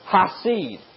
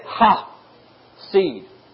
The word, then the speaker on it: chesed: And that mercy is not, that word mercy, let me share this with you, it comes from a Hebrew word, chesed. It's spelled C-H-E-C-E-D. But you say it, ha-seed.